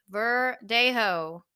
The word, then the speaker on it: Verdejo: Yes, and it is Verdejo. Yes, spelled Verdejo, but obviously the J is silent. So it's like Verdejo, like ho, ho, ho, like Santa Claus.